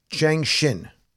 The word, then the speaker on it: Changxin: The X is usually pronounced S or SH. – Right. Changxin. So what do you think about that?